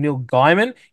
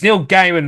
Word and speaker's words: Gaiman: And can I also just point out because you called him Neil Gaiman. It's Neil Gaiman, mate. He pronounced his name Neil Gaiman, not Neil Gaiman.